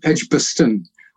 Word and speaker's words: Edgbaston: Where I lived was called Edgbaston. That's EDG, then Edgbaston. Edgbaston is a correct pronunciation.